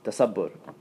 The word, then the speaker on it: tasabbur: Tasabbur. You can spell it as T-A-S-A-B-B-U-R.